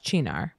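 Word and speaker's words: Cynar: I'm pretty sure it's pronounced Chinar. So it's spelled S-Y-N-A-R.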